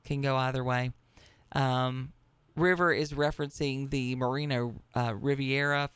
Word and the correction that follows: Mariano Rivera: former, but did I say that right? Mariano Riviera, a closing picture for the New York Yankees. – Mariano Rivera. – Mariano Rivera.